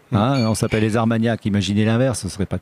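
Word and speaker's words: Arts Magnac: Rien que le nom, les Armagnacs. Ouais. Je l'ai pas dit à nos auditeurs, mais ça s'écrit Ar, A-R-T-S, Magnac, comme des maniaco-dépressifs.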